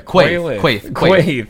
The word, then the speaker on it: Quaithe: The girl that was in Qarth, the Quaithe.